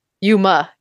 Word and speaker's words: Yuma: Yuma. Like 310 to Yuma. – Y-U-M-A. Yuma, Arizona.